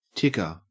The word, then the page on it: ticker: Australian English